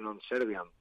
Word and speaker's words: non serviam: que realmente es una errata del latín, porque bien pronunciado sería y bien escrito es non serviam, es con A.